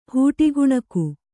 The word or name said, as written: ಹೂಟಿಗುಣಕು